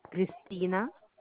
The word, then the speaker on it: Kristena: Christina, K R I S T E N A.